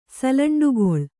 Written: ಸಲಂಡುಗೊಳ್

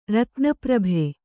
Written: ರತ್ನಪ್ರಭೆ

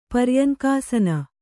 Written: ಪರ್ಯಂಕಾಸನ

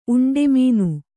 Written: ಉಂಡೆಮೀನು

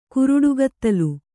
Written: ಕುರುಡುಗತ್ತಲು

